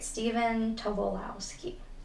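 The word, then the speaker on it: Stephen Tobolowski: They brainstorm ways to make money to pay a credit card bill after collections agent Steven Tobolowski shows up at Zerk's apartment. I think I said that right. Steven Tobolowski.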